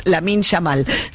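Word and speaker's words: Lamine Yamal: Yamil Lamal, abrazándolo. Lo dije bien, esta vez siempre lo digo al revés, Lamin Yamal, siempre le doy vuelta el nombre con el apellido,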